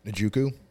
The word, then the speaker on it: Njoku: Njoku. – Thank you. – Oh, the tight end. – Yeah. – David Njoku, yeah.